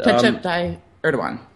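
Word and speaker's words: Tayyip Erdoğan: But Tayyip Erdogan.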